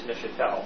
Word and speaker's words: Neuchâtel: The canton is Neuchâtel. – Oh, okay. – We're in the town of Marin, M-A-R-I-N.